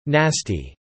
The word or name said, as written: nasty